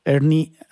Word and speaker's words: ERNIE: Ernie, así como se escribe, E-R-N-I-E, como nombre, así como de Beto y Enrique en inglés. Ernie-B chica I-L-G.